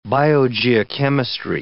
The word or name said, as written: biogeochemistry